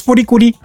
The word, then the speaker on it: FLCL: C'est ça se prononce Folikuli.